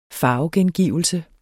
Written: farvegengivelse

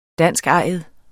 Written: danskejet

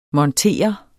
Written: montere

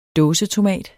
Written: dåsetomat

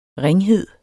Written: ringhed

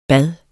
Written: bade

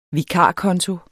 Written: vikarkonto

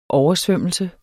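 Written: OVERSVØMMELSEN

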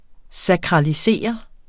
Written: sakralisere

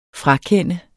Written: frakende

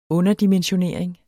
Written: underdimensionering

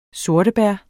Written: sortebær

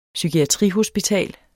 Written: psykiatrihospital

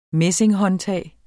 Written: messinghåndtag

